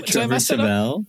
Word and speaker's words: Trevor Tevel: Trevor Tevel.